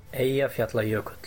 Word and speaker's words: Eyjafjallajökull: Eyjafjallajökull.